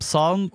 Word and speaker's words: Psalm: The other one is called Psalm West. – How do you spell that? – Like from the Bible, like P-S-A-L-M.